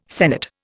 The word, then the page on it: senate: Indian